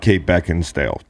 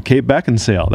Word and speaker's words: Kate Beckinsale: You're leaving out Kate Beckinsale. – Oh, and you're saying it wrong. Kate Beckinsale. That's right.